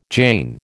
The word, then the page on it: Jain: American English